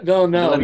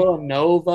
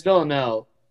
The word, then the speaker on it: Villeneuve: People also pronounce Villeneuve? It Villanova or whatever, but it's Villeneuve. And I only know this because I was like, how do you pronounce this? And I watched a YouTube video, and it's like the French pronunciation of it.